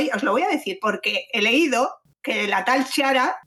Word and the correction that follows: Chiara: que no tengo ni idea porque no veo te Qui, Kiara, Kiara.